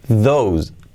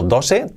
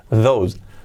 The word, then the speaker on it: those: Aquellos no se dice that, que sería aquello, en singular, pero en plural es those, those, those. He escrito dose, tose, those.